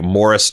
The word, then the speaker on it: Maurice: The English version of Maurice is pronounced like Morris Chestnut's name.